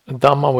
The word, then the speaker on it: Dhamma: One function of the term Dhamma, this is Dhamma with a long A at the end. Yeah, it's not the Dhamma of the Buddha.